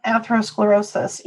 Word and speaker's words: atherosclerosis: Atherosclerosis.